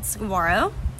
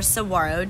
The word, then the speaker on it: saguaro: The name of the album that's coming out is called Saguaro, or Saguaro, depending on where you're from and how you want to pronounce it.